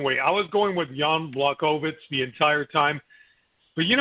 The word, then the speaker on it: Jan Błachowicz: I'm not going to call him Jan, because I called Jan Błachowicz. That's how you pronounce it.